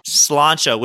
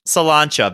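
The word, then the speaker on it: Sláinte: S-L-A-I-N. – Slancha, which means? – Drink up, baby. Cheers. – To life. L'chaim. Slancha.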